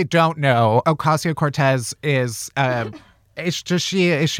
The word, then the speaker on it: Ocasio-Cortez: Ocasio-Cortez. That's how you say her name, right? – Yeah.